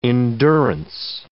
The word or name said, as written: endurance